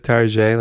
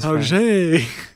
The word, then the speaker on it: Target: A vampire would definitely pronounce it Tarjay, like he's cool with his Tarjay.